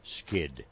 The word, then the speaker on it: skydd: Skid is spelled S-K-Y-D-D. – Yep. – Skid.